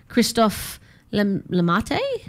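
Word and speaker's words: Christophe Lemaitre: He was regarded as the fastest man of non-African descent before Christophe Lamate.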